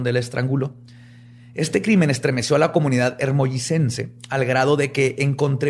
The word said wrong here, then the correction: Hermosillense: Espérate, ¿dijiste hermollicense? – Se dice así. Hermosillense, perdón. – No, es que me quedé trabado ahí. Hermosillense. – Es hermosillense.